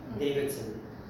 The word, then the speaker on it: Davidson: Davidson, D-A-V-I-D-S-O-N.